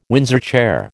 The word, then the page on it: Windsor chair: American English